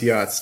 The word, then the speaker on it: Yats: Y-A-T-S, Yachts. – Y-A what? – Y-A-T-S, Yachts. – What is this? – Pronounced Yachts.